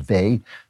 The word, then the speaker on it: Weil: Uh, her name is spelled Weil, but I think it's pronounced Vey. Simone Vey.